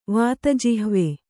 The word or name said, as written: ವಾತಜಿಹ್ವೆ